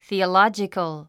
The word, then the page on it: theological: θìːəlάdʒikəl; スィオロジコゥ